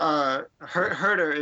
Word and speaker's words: Huerter: Huerter is always the way I figured it was pronounced. Huerter. – Yeah, that's okay. – Correct. – Kevin Huerter